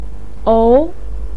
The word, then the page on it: ou2: pinyin